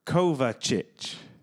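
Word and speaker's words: Kovačić: Mateo Kovacevic. – So you obviously watch Chelsea quite a bit. – Yeah. – Mateo Kovacic. – Kovacic. – Mateo Kovacic, very good player. – Is that how they pronounce it? – Yeah. – Kovacic.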